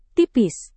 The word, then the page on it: tipis: Indonesian